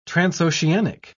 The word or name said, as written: transoceanic